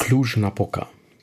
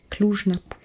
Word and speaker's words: Cluj-Napoca: Cluj-Napoca. – Cluj-Napoca.